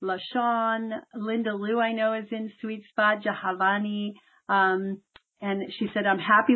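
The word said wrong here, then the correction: Jahnavi: You told me last week how to say it, Jahanavi.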